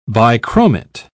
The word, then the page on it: bichromate: British English